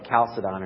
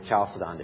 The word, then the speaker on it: Chalcedon: until we reach a church council called the Council of Chalcedon, or Chalcedon, depending on how you want to pronounce that.